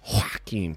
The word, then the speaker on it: Joaquin: Joaquin Phoenix.